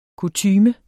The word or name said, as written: kutyme